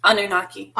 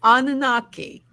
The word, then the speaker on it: Anunnaki: How do you say Anunnaki? – Anunnaki. – Anunnaki. – Thank you, okay.